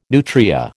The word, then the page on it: nutria: American English